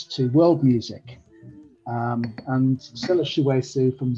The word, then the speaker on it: Chiweshe: You might have to help me pronounce this name. Stella Chiwazi, is that right? – That's it, yeah.